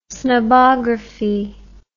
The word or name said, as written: snobography